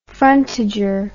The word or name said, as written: frontager